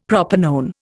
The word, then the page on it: propanone: Indian